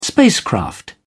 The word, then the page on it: spacecraft: British English